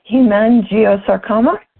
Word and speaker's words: hemangiosarcoma: The PetVen turkey tail research on, and you're going to have to help me pronounce this, canine hemangiosarcoma? – You did it right. Good for you.